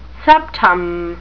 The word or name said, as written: septum